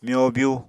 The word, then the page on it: myobyu: Apatani